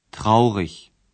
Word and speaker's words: traurig: Outros adjetivos você reconhece pela terminação I-G, que se pronuncia como ich. – Traurig.